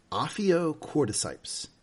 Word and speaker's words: ophiocordyceps: And she looks into it and she says, it's ophiocordyceps. Is that, am I saying that right? – Yeah, you are. Very well.